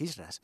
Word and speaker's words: Hijra: Lo primero que me gustaría deciros y contaros es qué son exactamente los Hijras. Bueno, digo Hijras, escrito se escribe Hijra, pero la pronunciación del sánscrito indio es Hijra.